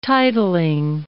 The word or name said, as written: titling